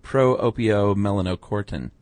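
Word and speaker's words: pro-opiomelanocortin: So leptin actually does this by turning down the production of the neuropeptide Y and cranking up the production of pro-opio-melanocortin.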